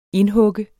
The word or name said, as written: indhug